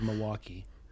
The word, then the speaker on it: Milwaukee: Well, I'm from Milwaukee, without the L. That's how we say it, Milwaukee.